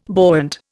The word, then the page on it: buoyant: Indian